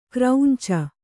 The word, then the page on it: ಕರಂಚು: kannada